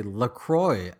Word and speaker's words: LaCroix: So on the website, they say LaCroix as in enjoy.